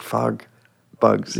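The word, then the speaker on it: FogBugz: You got the name right. That's good. – Yeah. – You're like fog bugs. – Yeah, yeah. Nailed it. Yeah. – And it's spelled with a Z.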